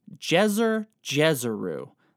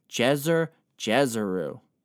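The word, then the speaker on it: Djeser-Djeseru: It is called Djeser Djeseru. That's a very fun word or title to say, so, you know, go ahead, say it after me. Djeser Djeseru.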